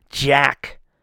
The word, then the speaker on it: Jack: Fucking commanded that stage with Zach. I can't believe I said his name wrong. So stupid. Jack Zabinski. I flipped my letters around.